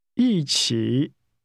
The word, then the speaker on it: 一起: 一起。 – This is pronounced with falling and falling rising tones.